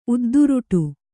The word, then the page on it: ಉದ್ದುರುಟು: udduruṭu